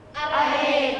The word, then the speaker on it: อเรศ: อเรศอร่อยอเรศอร่อยออกเสียงอะเต็มเสียงไหมต้องออกแบบไหนกึ่งเสียงนะ